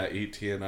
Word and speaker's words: Etienne: Etienne. – Yeah, Etienne.